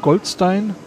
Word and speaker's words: Goldstein: Die Entwicklung von Ionenquellen begann bereits 1886 durch ja, Englisch oder Gold, äh, Deutsch aussprechen, Goldstein, Goldstein.